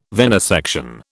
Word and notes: venesection: British English

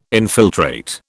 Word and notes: infiltrate: British English

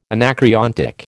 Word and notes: anacreontic: American English

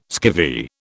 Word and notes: skivvy: British English

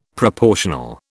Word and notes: proportional: British English

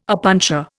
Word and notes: opuntia: Indian

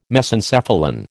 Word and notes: Mesencephalon: American English

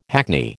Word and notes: hackney: American English

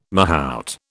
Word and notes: mahout: British English

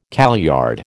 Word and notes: kaleyard: American English

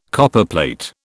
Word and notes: Copperplate: British English

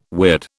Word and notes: weird: British English

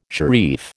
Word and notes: sharif: American English

